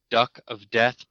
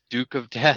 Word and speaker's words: Duke of Death: He keeps calling it Duck of Death, but it's Duke of Death.